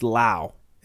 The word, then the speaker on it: Lowe: Another guy on the Rays, Brandon Lau. How many people do you know with the last name Lowe that's pronounced Lau? – Exactly 0.